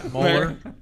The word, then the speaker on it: Mueller: It's Mueller.